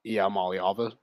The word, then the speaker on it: Iamaleava: But how much – Arch Manning isn't better than Nico Iamaleava.